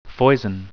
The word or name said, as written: foison